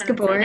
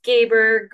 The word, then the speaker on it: Gabor: Literally, I don't think anybody can agree on like how our last name is pronounced, except what it probably technically is pronounced in Hungary, which is where our like ancestry is from. But it's Gabor growing up. Everybody else says Gabor, and then I think the traditional like pronunciation is Gabor. So at this point, we go by all three.